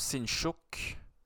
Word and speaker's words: Sinchuk: Et donc, Sinchuk, c'est comme ça qu'on prononce Sinchuk.